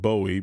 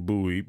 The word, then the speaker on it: Bowie: Bowie spelled like David Bowie, but we say it in Bowie because that's what we do. We don't know any better.